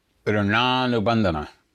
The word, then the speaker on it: rananubandhana: How do you pronounce that? – It's Rananubandhana. – Rananubandhana.